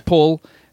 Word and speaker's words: Paul: But the way I pronounce it as an English person is Paul. The Americans think I'm saying pole. – Like pole dancer. – Like P-O-L-E, like pole dancer.